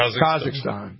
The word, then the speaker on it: Kazakhstan: I say Kakistan, but it's not Kakistan. It's like, I don't know. It's, you know. – Kazakhstan? – Yeah, thank you. Kazakhstan.